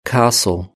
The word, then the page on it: castle: British English